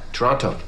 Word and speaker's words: Toronto: By the way, that movie is the reason why I know to say Toronto and not Toronto. – Where was your passport issued? – Vancouver. – Where were you born? – Toronto. – Toronto. – Canadians don't pronounce the T.